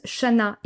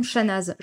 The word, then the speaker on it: Chanaz: Petit nota bene, comme toutes les villes du coin, cette ville se prononce Chana et non Chanaz.